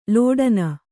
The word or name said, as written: ಲಾಟನ